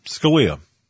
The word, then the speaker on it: Scalia: Did I say his name right again? Scalia? – Scalia. – Scalia, thank you.